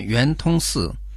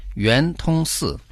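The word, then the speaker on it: Юэнтунсы (圆通寺): Разберем название Юэнтунсы. Юэн – круглый, произносится во втором тоне. Тун – бесперебойный, проникающий, произносится в первом тоне. А «с» раньше мы уже неоднократно упоминали это слово. Оно означает «храм», произносится в четвертом тоне.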